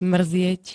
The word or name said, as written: mrzieť